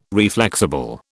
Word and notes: reflexible: British English

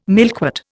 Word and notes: milkwort: Indian

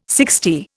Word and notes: sixty: Indian

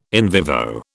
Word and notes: in vivo: British English

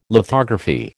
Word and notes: lithography: American English

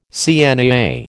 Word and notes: CNAA: American English

